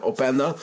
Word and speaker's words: Openda: heb, ja, zeg ik het goed, ja, Openda.